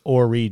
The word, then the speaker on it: Ori: Well, in that spirit, it's Ori Georgetown. The H is silent.